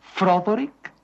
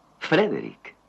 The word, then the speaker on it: Frederick: ¿Dice usted también Froderick? No, Frederick. ¿Y por qué no es Froderick Frankenstein? Porque no, es Frederick Frankenstein.